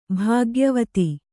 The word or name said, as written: ಭಾಗ್ಯವತಿ